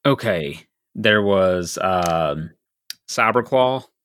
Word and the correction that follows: Saberclaw: Did I say that right? – What's Saberclaw? Close Saberclaw, sorry. Let's see here.